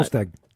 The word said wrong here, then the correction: Solskjaer: The clarity is that Ole Gunnar Solskjaer has left his role as Manchester United manager.